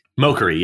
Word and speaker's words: Mochary: How do I say his last name? Mokery? – Mokery, yeah.